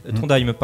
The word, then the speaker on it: Trondheim: et Lewis Trondheim, je crois que je le prononce bien. – Trondheim.